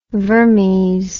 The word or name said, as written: vermes